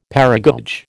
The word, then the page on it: paragoge: American English